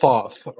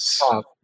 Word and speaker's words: Thoth: In my opinion, Hermes represents the Hellenization of the Egyptian god Tahuta, who's usually mispronounced Thoth. – Thoth. I remember when you showed me the actual pronunciation, I was blown away. – Well, it's T-H-O-T-H.